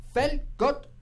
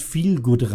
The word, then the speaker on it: Feel Good: Steht dort Fellgott Radio. – Ja, das steht so da, ja, das wird aber englisch ausgesprochen, das heißt Feel Good Radio.